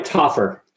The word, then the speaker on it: Taufer: Taufer.